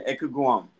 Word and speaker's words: Ekugwum: So my name is Stefan Ekugwum.